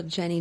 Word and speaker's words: Jennie: Yeah, if you just Google Jenny, J-E-N-N-I-E, mm-hmm. Baxter, we'll spell it because everyone thinks it's an X, but it's B-A-C-K-S-T-R-O-M,